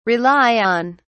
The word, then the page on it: rely on: British English